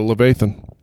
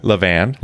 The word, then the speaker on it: Levan: All right, let's go to Levathan. Is that how you say it? – Levan. – Levan. Yeah, that. Levan. – Naval backwards.